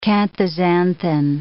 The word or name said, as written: canthaxanthin